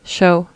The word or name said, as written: show